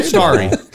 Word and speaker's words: Starry: What did I say? Starry? – No, you said Starry. Oh, I'm just reading the label. – Starry. I was like, oh, said it right.